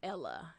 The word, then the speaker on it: Newsela: I don't know if it's Newsleah or NewsELA. – NewsELA is how they say it.